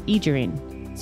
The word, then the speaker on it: Aegirine: Aegirine. So it's A-E-G-I-R-I-N-E, if you're not sure of the crystal.